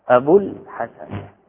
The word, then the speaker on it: أبو الحسن: نعم، كالشأن في جمع المذكر السالم، تقول جاء أبو الحسن، أبو الحسن، فما تنطق بالواو؟ الباء هذه مضمومة، أبو إذن الواو محذوفة للتخلص من التقاء الساكنين. جاء فعل ماضي أبو، تقول هذا فاعل مرفوع رفعه الواو المحذوفة للتخلص من التقاء الساكنين، أبو مضاف الحسن مضاف إليه.